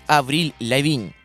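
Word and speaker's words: Avril Lavigne: Вообще, вроде как правильно говорить Авриль Лавинь, потому что девочка родилась э, в семье франко-канадцев, э, но как бы тефтели и тефтелями мы тоже не называем, так что сорян, будем так, как привыкли.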